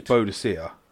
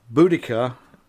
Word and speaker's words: Boudica: It was Boadicea. – Boadicea is how I would pronounce it. – Yeah, that's how I pronounce it. – Boudica is how it's pronounced. – Is how it's pronounced now.